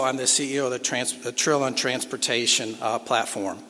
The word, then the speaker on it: Trilon: One of the ways to remember how to pronounce Trilon is it rhymes with chillin.